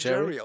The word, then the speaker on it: Gerry: Nee, aber äh, mein Name wird tatsächlich im Amerikanischen wird der Jerry, Jerry ausgesprochen. Ist tatsächlich, hat es richtig gemacht. – Auch mit G? Ja. Wirklich. Jerry Seinfeld? Es wird praktisch wie Jerry Seinfeld ausgesprochen. Es gibt äh, ein G-A-R-Y und der wird Gary.